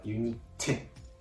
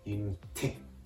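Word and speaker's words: unité: ですから、ユニテ、ユニテ、多少てのところを強く発音してあげるとフランス語の発音になります。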